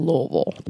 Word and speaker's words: Louisville: And you got to say it like that, Louisville. Like you got marbles in your mouth, but it was a great like city and town, but it was so, oh man, it was so hectic.